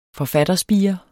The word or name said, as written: forfatterspire